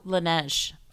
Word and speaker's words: Laneige: I have to shout this out with, and I'm going to pronounce it wrong. Langley. – Oh, Laneige.